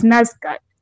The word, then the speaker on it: NASDAQ: And when it actually came out, because I think Havas worked with Facebook, they worked with, I always say it wrongly, it's NASDAQ. – NASDAQ.